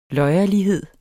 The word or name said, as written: løjerlighed